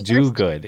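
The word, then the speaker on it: Dogood: It's Silence Do-Good. It's Silence Do-Good. And I said Silence Dogwood because I misread it.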